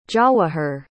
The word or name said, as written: jawahir